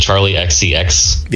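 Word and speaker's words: Charli XCX: I'm going to murder the pronunciation of this because I'm sure it's something else, but Charlie XCX. – Yeah, I think that's what it goes by. Or she goes by. – Okay. – Yeah, you pronounce the XCX.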